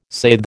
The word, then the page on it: saithe: American English